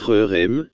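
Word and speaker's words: herem: Maintenant, RERM, ça se prononce RERM, c'est un H dur, RERM signifie, destruction totale.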